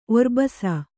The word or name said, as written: ಒರ್ಬಸ